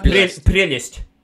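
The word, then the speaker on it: prelest: Am I pronouncing right? Prelast? Perlast? – Prelist. – Yeah, prelist, yeah.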